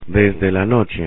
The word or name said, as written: desde la noche